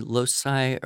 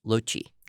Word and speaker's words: loci: Latin, but it could be loci or loci or loci. – What did you call loci. – It? – I probably used the pronunciation that I taught, which would be loci. – Yeah, okay. – L-O-C-I.